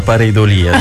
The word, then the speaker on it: Pareidolia: Abbiamo già risolto la questione dell'accento e ci avevamo preso, era Pareidolia, giusto? – Sì, è già un buon inizio.